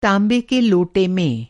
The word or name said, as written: ताँबा